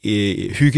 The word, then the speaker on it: hygge: It's spelled H-Y-G-G-E. – So it's pronounced hygge.